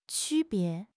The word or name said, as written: qu1 bie2